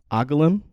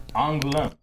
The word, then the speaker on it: Angoulême: Agalim Angoulême.